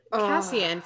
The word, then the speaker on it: Cassian: Like, who's Kaysen? So there's a new person. Fuck. Yeah. – Dude. – Cassian, first of all, isn't it Cassian? – Cassian. Oh, I'm saying it right.